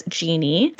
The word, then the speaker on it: djinn: So the Alp, along with other folklore creatures, such as the night hag, the Egyptian djinn pronounced as genie, the Italian pon da fecchi, and more, is what people would essentially reference as being the causation for sleep paralysis.